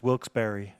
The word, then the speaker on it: Wilkes-Barre: I thought it was Wilkes-Barre too, but the people there pronounce it as Wilkes-Barre.